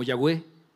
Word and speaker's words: Yahweh: Yahvé, que se puede pronunciar como Yahweh o como Jehová, es exactamente lo mismo, por eso es una forma como tratar de decir, así lo podemos pronunciar, porque no tiene vocales.